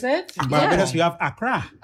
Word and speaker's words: Accra: But I grew up all of my life calling it Accra. I was told here it's called Accra.